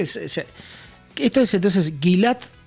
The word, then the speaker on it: Gilad: A ver, no sé cómo será originalmente, es G-I-L-A-D, Gilad, o sea, Gilad, sí.